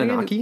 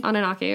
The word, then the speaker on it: Anunnaki: They're the Anunuka, the council. I totally said that wrong. What's it called? How do you pronounce Anunnaki? – It? Anunnaki. All right, sorry. – That's how they pronounce it in Ancient Aliens, anyway.